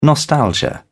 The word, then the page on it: nostalgia: British English